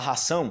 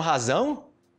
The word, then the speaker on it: razão: Então, se você fez a pronúncia, tipo, uma, uma pronúncia que o pessoal erra muito em português, tipo, razão, fala ração, ah, você tem ração, ah, você acha que tem um razão? Ah, sem razão.